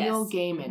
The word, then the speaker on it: Neil Gaiman: Neil Gaiman. – Yes. Neil Gaiman.